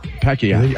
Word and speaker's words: Pacquiao: That's how they say it. Pacquiao.